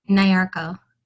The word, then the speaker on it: Nyarko: Is that how to say your last name? – Nyarko. – Nyarko.